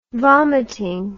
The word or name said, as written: vomiting